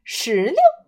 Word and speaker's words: 石榴: 溜溜石榴，嘿嘿，别忘了，别忘了，最后那个字儿是轻声啊，石榴。